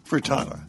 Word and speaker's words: frittata: But Zorba, before our next call, asparagus and smoked Gouda frittata.